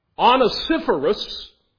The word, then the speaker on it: Onesiphorus: I have been pronouncing it Onesiphorus. That may be right. Onesiphorus, that is probably right.